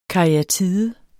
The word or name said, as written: karyatide